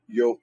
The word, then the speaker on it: Joel: We're back in the book of Joel, and again, it's Joel, Yoel, the way it would really be pronounced.